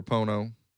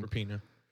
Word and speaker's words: Rapinoe: Megan Rapinoe or Rapono. I'm probably saying that wrong. – Rapinoe.